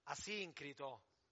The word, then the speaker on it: Asincrito: Salutate, come si pronuncia? Asincrito? Asincrito, dice la nostra lettrice.